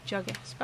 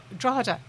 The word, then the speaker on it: Drogheda: Is that how you pronounce it? – Drogheda. Drogheda.